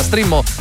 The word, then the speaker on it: Streammo: che si pronuncia Streamo ma si scrive Streammo.